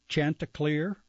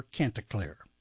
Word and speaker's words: Chanticleer: Saturday, November 3rd, okay, here's one you can pronounce either way, Chanticleer or Canticleer. That's what they told me anyway.